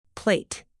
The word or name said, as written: plait